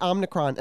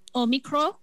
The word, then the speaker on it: Omicron: Well, apparently the Greeks call it Omicron. – Oh, really? – With no N, or Omicron, uh-huh. – which is the Greek pronunciation.